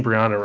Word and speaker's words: Briana: Briana Morgan, and please tell me if I'm saying Briana right, if I'm pronouncing that right.